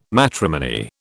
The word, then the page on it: matrimony: British English